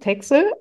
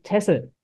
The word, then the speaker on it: Texel: Wir Deutschen sagen Texel, aber die Niederländer nennen die Insel Tessel mit Doppel-S.